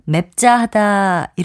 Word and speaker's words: 맵자하다: 참고로 맵자하다로 표기되고 맵자하다 이렇게 발음되는 형용사가 있습니다.